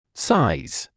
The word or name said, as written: size